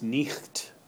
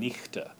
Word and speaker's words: knight: So the word "knight" um, was pronounced nicht, or um, with an e at the end, nichte.